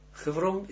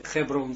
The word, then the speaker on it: Hebron: Hebron zeggen ze. Hebron is dat. We moeten ook zo uitspreken. Hebron en niet Hebron. Het Hebron, daar staat, dat is dan, zit daarin het woord de stam het bet resh.